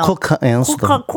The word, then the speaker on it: 쿼카앤 수달: 어, 예, 살짝 이거는 원래도 좀 발음 살짝 섞어줘야 돼요. 쿼카앤 수달. 그러니까, 그러니까 너무 정식적으로 가면 안 돼. 아, 예. – 혀를 약간 천장에 대야겠네요. 어, 쿼카앤 수달.